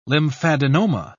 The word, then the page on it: lymphadenoma: British English